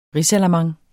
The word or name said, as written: ris